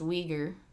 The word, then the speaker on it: Uyghur: Uyghur.